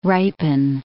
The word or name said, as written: ripen